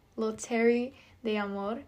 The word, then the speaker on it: Loterie d'Amour: One tradition Valentine's Day event in France was the, oh, I'm gonna butcher this, Loteria d'Amour. Let's see how you pronounce that. Loteria. Let's see. Pronunciation. How do you pronounce? Oh, d'Amour. There you go. Oh, look at me, French. Loterie d'Amour, in which hopeful singles would line.